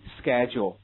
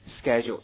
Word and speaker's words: schedule: 时间表或者时间安排 schedule 这个单词叫做时间表或者是日程安排英国人怎么读的？跟我读一下 schedule 这是英式读法或者是你们课本上读法。如果美国人读呢 schedule， OK， schedule 区别很大的。